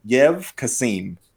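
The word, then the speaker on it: Yev Kassem: His name is, and I may be pronouncing it wrong, Yev Kasim. – Sounds correct, pronunciation-wise.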